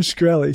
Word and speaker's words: Shkreli: Martin Shkreli.